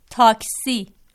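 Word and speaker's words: تاکسی: جیسا کہ ہم عرض کر چکے ہیں کہ فارسی میں چونکہ لفظ ٹے موجود نہیں ہے، لہذا ٹے کی جگہ تے استعمال کیا جا رہا ہے یہاں پہ، اور تلفظ جو ہے ٹیکسی کا کیا ہے؟ – ٹیکسی. – جی ہاں، ٹیکسی.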